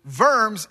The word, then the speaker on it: Worms: And Worms, or Worms is the way we would pronounce it, Worms is a city in Germany.